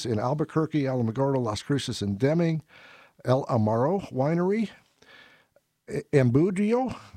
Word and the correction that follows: Embudo: yeah, I'm sure I pronounced Embudio. Maybe I got that right. Embudio Valley, E-M-B-U-D-O, Embudo Valley Winery. Let's see, Enchanted Vine, Enchanted Vineyard, Fort Selden Winery, Gruet, G-R-U-E-T,